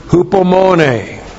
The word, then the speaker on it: hupomone: Virtue number 1 that is produced is the word hupomone. Hupomone, H-U-P-O-M-O-N, long E, hupomone.